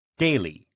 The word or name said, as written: daily